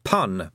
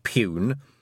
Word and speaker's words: pun: It's pun, by the way, not pune.